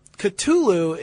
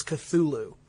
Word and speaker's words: Cthulhu: But Cthulhu is one that I think most Lovecraft scholars would argue is the appropriate pronunciation. – Right. – But the way I've always said it and the way that most of the fans that I've talked to have always said it is Cthulhu.